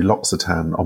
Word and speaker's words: L'Occitane: And it turns out I've been saying it wrong all the years. So it's L'Occitane.